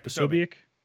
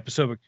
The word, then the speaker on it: Posobiec: But it's something that Jack, how do you say his last name? Posobiec? Yeah. – Jack Posobiec. – Yeah, Posobiec.